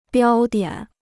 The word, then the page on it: 标点: biāo diǎn